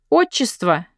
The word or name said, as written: patronymic